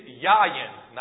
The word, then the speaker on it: yayin: It's Yayin, not Yamin. 2 weeks you had one job there. It's Y-A-Y-I-N, okay? Y-A-Y-I-N. Sorry about that. The word Yayin, it's pronounced Yayin, okay?